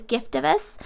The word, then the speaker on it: Giftovus: And you guys, what we're talking about is Giftovus. Is that how you pronounce it? – Yes.